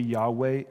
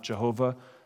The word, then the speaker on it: Yahweh: Many Hebrew scholars today agree that the closest pronunciation is probably Yahweh and not Jehovah.